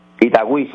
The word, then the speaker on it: Itagüí: Itagüí, sí.